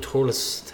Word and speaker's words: Thurles: Thurles.